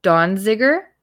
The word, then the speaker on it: Donziger: And I do believe in the original episode, I do mispronounce the name as Donziger, but there's no N, so it's Donziger.